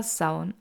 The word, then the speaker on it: Samhain: Und damit fangen wir direkt an mit dem Titel, der Samhain geschrieben wird, aber Saun ausgesprochen wird.